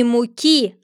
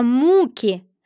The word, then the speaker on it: муки: «Начинается с муки». – «Ты делаешь неправильное ударение», — перебила ее Черная Королева. – «Надо говорить не муки, а муки.